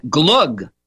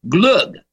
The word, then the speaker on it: glögg: So I made sure we were drinking a beverage from Sweden called Glögg, G-L-O with an umlaut over it, G-G, Glögg.